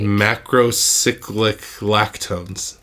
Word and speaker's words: macrocyclic lactones: Macrocyclic lactones.